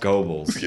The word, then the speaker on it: Goebbels: no, it's pronounced Goebbels.